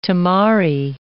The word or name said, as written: tamari